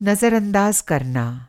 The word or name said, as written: नज़र अंदाज़